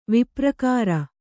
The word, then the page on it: ವಿಪ್ರಕಾರ: viprakāra